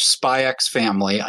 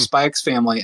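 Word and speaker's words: Spy × Family: I watch Spy X Family. I know you're not supposed to pronounce the X, Jacob, but I watch Spy X Family and I adore it.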